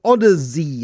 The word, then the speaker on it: Odyzzey: Uh, Danielle Towsen is the head of marketing of a, bu- a family business called Odyzzey. It is like an Odyssey, but it's spelled with Z's instead of S's.